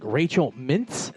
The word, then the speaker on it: Rachel Mintz: Rachel Mintz.